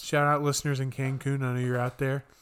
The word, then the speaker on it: Cancun: Just got back from Cancun, not Cancun. – Cancun, what? Cancun. Is that really how you say it? – Yes, Cancun, Mexico.